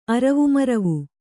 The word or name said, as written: ಅರವುಮರವು